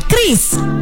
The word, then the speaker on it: QRIS: Ada yang menyebut QRIS, QRIS, atau ORIS. Kepala Grup Departemen Kebijakan Sistem Pembayaran DKSPBI, Fitria Irmi Triswati mengungkapkan, pengucapan yang benar adalah QRIS.